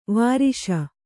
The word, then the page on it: ವರ್ಷ: Kannada